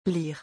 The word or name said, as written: Lire